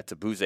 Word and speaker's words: Tabuse: I believe his name was Yuta Tabusi. Tabuse. I'm not sure if I'm saying that right. Um, Tabuse, yeah. yeah, Tabuse,